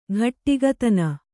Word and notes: ಗಟ್ಟಿಗತನ: gaṭṭigatana

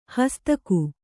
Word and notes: ಹಾಸ್ತಿಕ: kannada

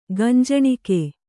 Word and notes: ಗಂಜಣಿಕೆ: Kannada

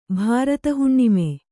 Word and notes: ಭಾರತಹುಣ್ಣಿಮೆ: kannada

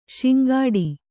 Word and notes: ಶಿಂಗಾಡೆ: śingāḍe

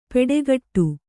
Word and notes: ಪೆಡೆಗಟ್ಟು: peḍegaṭṭu

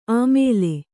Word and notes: ಆಮೇಲೆ: Kannada

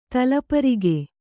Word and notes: ತಲಪರಿಗೆ: talaparige

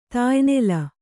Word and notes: ತಾಯ್ನೆಲ: Kannada

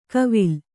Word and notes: ಕವಿಲ್: Kannada

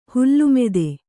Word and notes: ಹೊಲೆಮಾತು: hole mātu

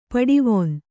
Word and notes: ಪಡಿವೊನ್: paḍivon

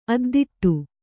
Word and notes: ಅದ್ದಿಟ್ಟು: addiṭṭu

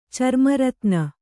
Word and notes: ಚರ್ಮರತ್ನ: carma ratna

